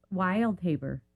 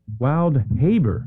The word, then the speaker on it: Wildhaber: T-O-N-I. – Okay, and your last name? – Wildhaber. – Spell it for me. – W-I-L-D-H-A-B-E-R. – H-Wild-H-A-B-E-R? – B-E-R, yes. – Wildhaber. – Wildhaber, yeah. And not to be confused with wild behavior.